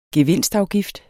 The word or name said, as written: gevinstafgift